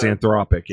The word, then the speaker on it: Anthropic: Let's talk about Anthropic. That's how you pronounce it, right? The people behind... – Yes, Anthropic, yes.